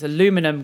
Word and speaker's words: aluminium: Let's not talk about the other words that you pronounce differently, because aluminum gets me angry every time. – Aluminium.